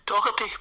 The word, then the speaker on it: Doherty: Neil Doherty or Doherty? – Doherty, please, Johnny.